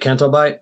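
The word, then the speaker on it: Canto Bight: And going back to the, was it Cantobite? Is that how you pronounce it? – Yeah.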